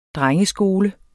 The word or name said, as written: DRENGESKOLEN